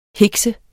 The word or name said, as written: hikse